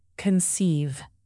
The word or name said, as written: conceive